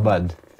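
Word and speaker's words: Aubade: E, ο Bad προφέρεται. A-U-B-A-D-E. Ο Bad.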